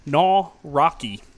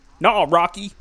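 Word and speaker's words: Nawrocki: Phil Vischer and Mike Nowak. I can't even say his name. Mike Nowrocki. Nowrocki.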